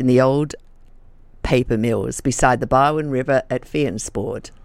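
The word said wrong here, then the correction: Fyansford: I hope you say it like that. – Fiensford. Yeah.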